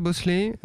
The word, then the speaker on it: Bhosle: Voilà, c'était Asha Bosley, ça s'écrit Bhosle.